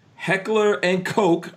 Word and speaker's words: Heckler and Koch: Heckler and Koch.